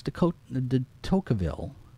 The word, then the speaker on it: Tocqueville: Alex de Tocqueville. I'd say that right. De Tocqueville.